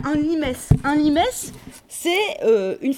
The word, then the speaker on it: limes: Vous voyez L-I-M-E-S, ça se prononce limes. En latin, on prononce toutes les lettres.